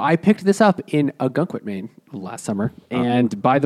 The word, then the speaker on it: Ogunquit: Ogunquit, yeah.